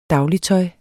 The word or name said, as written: dagligtøj